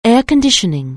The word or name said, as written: air-conditioning